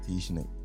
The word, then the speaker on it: Dejanay: My name is pronounced Dejanay. – Dejanay. – Everyone knows me as DJ. – So the A's is silent? – Yes.